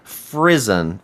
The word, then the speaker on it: frisson: Um, it happens to her as well, and it's called frisson. Frisson, I think is how you say it. It's F R I S S O N.